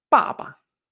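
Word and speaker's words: papa (爸爸): Papá en chino es la última combinación, la 4 con el tono neutro, que es papa. Papa.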